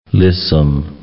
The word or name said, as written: lissome